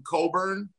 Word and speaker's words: Cockburn: No, they got this kid called, they call him Coburn, but his name is spelled Cockburn.